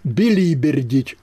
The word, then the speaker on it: билибердить: Еще хороший был глагол «билибердить» – «удаля» с ударением на «и».